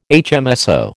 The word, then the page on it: HMSO: American English